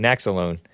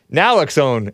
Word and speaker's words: Naloxone: Oh, am I pronouncing it incorrectly? I called it Naxalone. Naloxone.